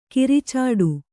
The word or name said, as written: ಕೊರಚಾಟ